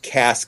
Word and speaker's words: Cass: Cass County, but of course Texans often pronounce it Case County, which is again northeast Texas-ish.